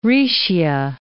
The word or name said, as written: Raetia